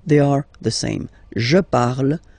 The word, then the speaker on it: parle: and then the way to pronounce it is je parle, parle. So remember, we put it, okay, but then we don't really pronounce it, je parle, okay.